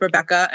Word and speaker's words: Rebekah: So I'm on all of the socials at Rise with Rebekah, and my first name is spelled a little differently, so you can put that in the show notes. It's with a K-A-H at the end instead of a C-C-A.